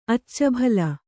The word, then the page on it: ಅಚ್ಚುಬೆಲ್ಲ: accubella